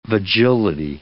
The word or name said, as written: vagility